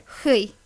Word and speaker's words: Huy: H-U-Y. How would you pronounce that? – H-U-Y. Hui.